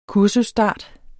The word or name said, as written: kursusstart